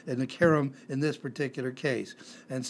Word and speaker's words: cherem: The Hebrew word is cherem, C-H-E-R-E-M, C-H-E-R-E-M, not harem, but cherem, a C-H sound like in church, only we pronounce it as a K.